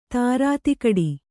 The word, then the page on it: ಧೀರತೆಗಿಡು: kannada